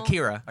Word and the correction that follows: Acura: Acura, the Honda.